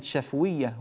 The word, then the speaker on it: شفوية: قال الناظم رحمه الله وسمها شفوية، كذلك كلمة شفوية هنا بسكون الفاء.